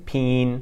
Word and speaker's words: pinyin: And this always cracks me up because saying pinyin is a lot like saying the word peeing in English once you nasalize it a little bit and speak quickly. So instead of pinyin, you're saying peen.